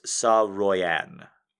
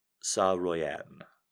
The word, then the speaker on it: Saroyan: I'm getting a pronunciation from the text chat. It's Saroyan. Saroyan.